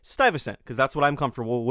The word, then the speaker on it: Stuyvesant: New Yorkers will say Peter Stuyvesant. That's what I grew up with. However, I heard Charles Gehring, who is the know-all and end-all of the New Netherland Institute, and he pronounces it, probably in perfect 17th century Dutch, as something close to Stuyvesant, or Stuyvesant, something like that.